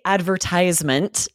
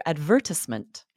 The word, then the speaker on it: advertisement: So this one is, we say advertisement in the US, most of us anyway. And in England, you would likely hear advertisement. – It's just so fun. Yeah. So US advertisement, right? For syllable stress. But advertisement, the stress on the second syllable, again, it sounds educated.